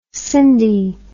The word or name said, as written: Sindy